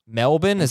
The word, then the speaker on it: Melbourne: Melbourne? That's how it's pronounced? – Exactly. See, that's how you know I'm a true someone who's been there, unlike you, who just reads on a piece of paper and would assume it's Melbourne, but it's not Melbourne.